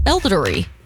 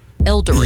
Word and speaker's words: Elordi: Are double dating with Timothy Chalamet and Jacob Elordi. – Elordi. You said it wrong.